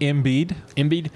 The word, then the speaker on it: Embiid: Embiid.